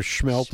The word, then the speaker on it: Smelt: It's up in the Bayfield Peninsula area, and they're having on the 28th, of April, they have their annual Schmelt Fry. Smelt is the way it's spelled, but Schmelt is the way it's pronounced.